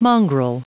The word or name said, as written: mongrel